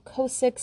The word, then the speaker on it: Ciosek: Root is spelled R-O-O-T, or you can find me on Facebook under the name M-E-M Kosick, C-I-O-S-E-K.